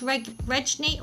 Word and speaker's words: Regni: She told them they were visiting the Regini. I'm not sure if I'm saying that right. – I think it's Regni or Regni tribe.